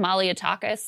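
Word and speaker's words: Malliotakis: But one of the members of Congress who voted for this, how do you say her last name? Maliotakis? – Yes. – Nicole Maliotakis.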